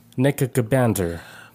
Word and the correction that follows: Nebuchadnezzar: Somebody on the... Nebuchadnezzar. – Nebuchadnezzar? Nebuchadnezzar. – Nebuchadnezzar. – Yeah, Nebuchadnezzar. Because it says Chad in the middle.